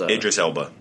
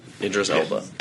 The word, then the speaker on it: Idris Elba: Idris Elba. – Idris Elba.